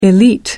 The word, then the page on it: elite: American English